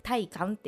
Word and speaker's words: 大観: とはいえねとはいえいやぶっちゃけですよ横山大観大きい観光って書いて大観って読ませ